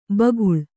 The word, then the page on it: ಭೂಗೋಳ: bhū gōḷa